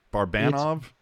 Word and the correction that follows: Barabanov: Barabanov. – So yeah, I'm like Barabanov, Barabanov.